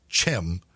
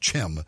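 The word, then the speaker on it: Thiem: Mustafa, I think they pronounce it Chim. It's T-H-I-E-M, but I think it's pronounced Chim.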